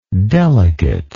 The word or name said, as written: delicateness